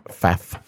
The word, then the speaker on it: Pfaff: And they're going to reach out and say, hey, you know, Bob Smith, you know, whatever, Darren Pedderfee, Nicole Pfaff, you know, whatever. – Pfaff. – Silent P.